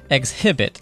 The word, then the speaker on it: exhibit: All right, the third word we have is exhibit, and that's E X H I B I T, but it sounds like exhibit, but the X, but the H is silent, so you could just say exhibit.